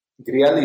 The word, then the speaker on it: Grial: Grial is how you pronounce it.